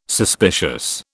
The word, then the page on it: suspicious: British English